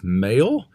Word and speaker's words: Mhaol: Is a band called, I looked this up, it's pronounced Mail, but it's spelled M-H-A-O-L and has some parentheses somewhere in there.